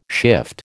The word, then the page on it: shift: American English